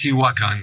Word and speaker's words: Teotihuacan: So did this trip actually involve going to, and I'm going to see if I can get the pronunciation right, Teotihuacan? Is that correct? – Teotihuacan, yes.